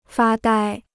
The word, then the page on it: 发呆: fā dāi